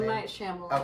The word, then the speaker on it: Shyamalan: M. Night Shyamalan.